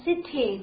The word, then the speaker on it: city: 注意那个 T 的读法，就是英音和美音也是不大一样的。在英音里面呢，我们读成 city，对不对？这个 T 呢是放弃的，你看一下，啊，city，好，city，对不对？但是在美音当中，这个 T 是不放弃的，它读的是有点像的的那种读法，但是比的呢要读的稍微轻一点啊。